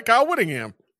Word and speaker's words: Kyle Whittingham: Wait, am I saying it right? Am I saying Kyle? Wait, hold on. – Yeah, go look. Maybe you're saying it right. Maybe I'm getting it twisted. Maybe I'm getting the name. – Kyle Whittingham. I was right.